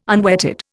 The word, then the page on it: unwetted: Indian